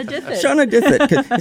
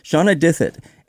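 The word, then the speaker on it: Shanawdithit: Shauna Dithit. – It's that W in there that keeps wanting me to do something with it. Shauna Dithit.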